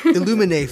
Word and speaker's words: Illuminae: I know I pronounced it wrong, so I'm gonna say files. With the Illuminae files. – Illuminae files. – Yeah, perfect.